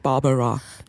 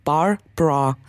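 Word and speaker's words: Barbra: Sie heißt nicht Barbara. – Sondern? – Barbara.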